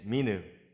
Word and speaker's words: Minu: The correct way to say it is Minu, emphasizing the first syllable.